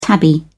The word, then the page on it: tabby: British English